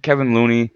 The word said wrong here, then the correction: Kevon Looney: Let me make sure I'm saying his name correctly. Yeah, Kevon Looney.